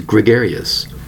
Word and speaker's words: gregarious: Today's word is gregarious. Gregarious. That's right, four syllables for the word gregarious. Spelled G-R-E-G-A-R-I-O-U-S.